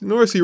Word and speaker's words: Norsey: It's Norsey rhymes with horsey.